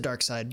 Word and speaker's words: Darkseid: They pronounce it Darkseid, but it's spelled funny.